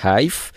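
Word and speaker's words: HEIF: Genau, äh, ich weiß nicht genau, wie sie es aussprechen, aber es heißt Hive.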